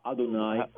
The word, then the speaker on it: Adonai: Adonai, very Orthodox Jews don't even say that, but that's the word we translate as Lord.